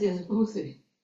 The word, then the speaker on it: feng shui: Is that what it's called? Feng shui? – Feng shui. – Yes, feng shui. – Feng shui. – Oh, yeah, that's right.